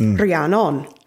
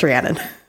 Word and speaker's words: Rhiannon: If you are my mother and angry, then it's Rhiannon. But if you're just neutral, then it's Rhiannon.